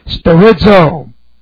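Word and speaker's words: Sterizo (στηρίζω): Active imperative of sterizo. Sterizo looks like this. That's S-T, long E-R-I-Z-O. Sterizo.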